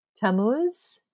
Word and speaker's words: Tammuz: In Ishtar's case, she loved a vegetation god, Tammuz. Am I pronouncing that correctly? Tammuz? – Yeah, it sounds about right.